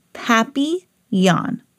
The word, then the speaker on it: Papillon: You say it like this, Papillon. In French, it means butterfly.